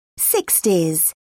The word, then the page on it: sixties: British English